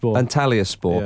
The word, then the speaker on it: Antalyaspor: Samuel Eto'o has been playing in Turkey, as you well know, at Antalyaspor. I think that's how you say it. – Antalyaspor. – Yeah.